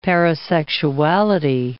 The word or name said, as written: parasexuality